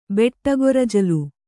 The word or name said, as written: ಬೆಟ್ಟಗೊರಜಲು